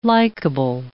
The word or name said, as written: likable